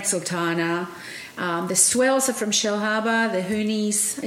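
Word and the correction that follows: Hunnies: is that how you pronounce it? – The Honeys.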